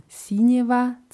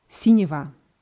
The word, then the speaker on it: синего: Sinjeva wird aber das G wird immer noch wie W ausgesprochen. In dem Dialog aus der Hörprobe sagen die einfach Sinjeva.